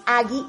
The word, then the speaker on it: Aggi: Es Agi y se escribe A-G-G-I .mx ahí es la página,